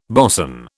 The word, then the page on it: bosun: British English